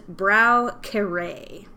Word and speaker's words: Braucher: It's a German word. And the way it's pronounced is Braukere.